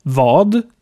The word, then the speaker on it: vad: Wir haben auch noch vad, was bedeutet, aber als war oder wa ausgesprochen wird.